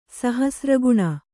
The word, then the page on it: ಸಹಸ್ರಘ್ನಿ: Kannada